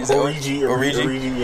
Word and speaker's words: Orji: It's how good Yvonne Orji, or how do you say her last name? – Origi, yeah.